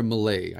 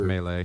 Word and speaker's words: melee: But Super Animal, is it pronounced Melee or Malay? I can never. – It's Melee.